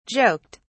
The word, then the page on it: joked: British English